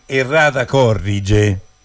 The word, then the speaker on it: errata corrige: No, la pronuncia esatta adesso ve la faccio dire direttamente da una fine dicitrice. Come si pronuncia? Io prima, sbagliando, ho detto, quindi la pronuncia. Eccola. – Errata corrige. – Errata corrige. Questa è l'esatta pronuncia latina di questa espressione.